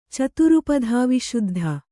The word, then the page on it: ಚತುರುಪಧಾವಿಶುದ್ಧ: caturupadhā viśuddha